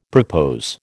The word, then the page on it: propose: American English